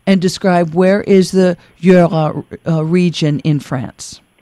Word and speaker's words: Jura: And tell us how to pronounce it correctly. Is it Jura? – It's Jura.